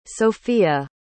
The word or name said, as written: sofía